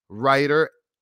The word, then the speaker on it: Reiter: and his name is Alex Reiter. And I keep forgetting how to say the last name. Reiter? Yes, I remember you said Reiter, like the writer.